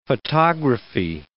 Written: photography

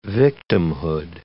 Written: victimhood

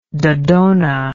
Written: dodona

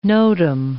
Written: notum

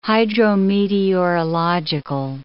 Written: hydrometeorological

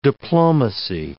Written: diplomacy

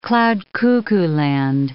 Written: cloud-cuckoo-land

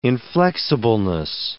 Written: inflexibleness

